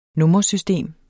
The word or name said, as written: nummersystem